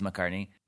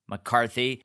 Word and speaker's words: McCarthy: Great job, Melissa McCartney. McCarthy.